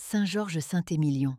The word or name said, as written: I.S.V.V